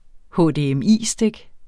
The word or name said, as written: HDMI-stik